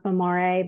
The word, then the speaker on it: Memore: Memory is mindful in Italian, and it's actually pronounced memore, but we just say very English, like American way of memory.